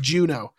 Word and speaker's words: Juneau: Pronounced Juno.